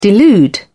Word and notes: delude: British English

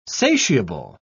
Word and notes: satiable: British English

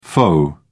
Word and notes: faux: British English

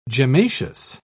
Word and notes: gemmaceous: British English

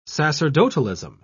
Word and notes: sacerdotalism: British English

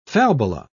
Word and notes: falbala: British English

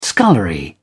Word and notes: scullery: British English

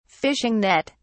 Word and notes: fishing net: British English

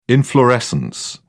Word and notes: inflorescence: British English

